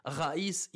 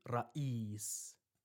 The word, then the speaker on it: raïs: Le R roulé dont raïs est remplacé par le R français ou le son de rain » en arabe pour dire raïs et non plus raïs.